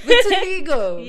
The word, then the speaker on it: vitiligo: How is someone supposed to speak to someone who is experiencing vitiligo? Like, vitiligo. I keep getting this word wrong. I've been practicing it. Vitiligo.